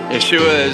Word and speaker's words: Yeshua: Yeshua is the Hebrew pronunciation of Jesus.